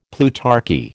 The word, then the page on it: plutarchy: American English